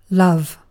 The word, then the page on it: love: British English